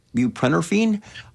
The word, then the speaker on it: buprenorphine: Buprenorphine.